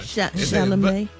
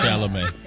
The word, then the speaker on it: Chalamet: Chalamet. – Oh, that's how you say it. – Yeah, Chalamet.